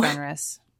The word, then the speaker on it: Fenris: Justice for Fenris. Did I say his name right? – Yes. – Yes, Fenris, I did get it right. Anders, nice. – Yeah. It's the Fenris, like Fenris Wolf.